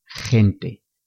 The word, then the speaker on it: gente: La G con la vocal E y la G con la vocal I se pronuncian igual que la J, con un sonido fuerte desde la garganta. G, G. Con la vocal E, gente.